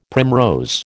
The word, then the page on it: primrose: American English